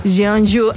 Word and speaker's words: Jeonju: y recientemente estrenada en el Festival de Jeonju, o Jeonju, no sé si lo pronuncio bien. – Jeonju, en Corea.